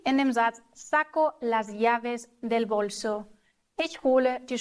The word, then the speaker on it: llaves: Achtung, wir sagen nicht laves, sondern wir sagen llaves, denn das Doppel-L wird im Spanischen immer ausgesprochen wie ein deutsches J.